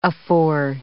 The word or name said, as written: afore